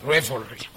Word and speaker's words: revelry: It's written reverry, but it says reverry. How do you pronounce this? There is a U in pronunciation. Reverry. Anyone can help how to pronounce that? Reverry. Re? revolution. I don't know where the U is coming from. That's why I'm struggling when I was trying to pronounce it. – Revelry. – Okay.